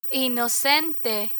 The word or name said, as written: inocente